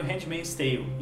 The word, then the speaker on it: Handmaid's Tale: E hoje vamos fazer sobre, falar sobre esse seriado que balançou bastante gente, está ganhando bastante prêmio, Handmaid's Tale.